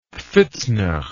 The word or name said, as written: pfitzner